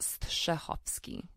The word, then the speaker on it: Strahovski: Well, you know, it's actually for real pronounced Strahovski. – Wow. – Because it's a Polish last name, and I spell it phonetically now to make it a little easier for people to pronounce.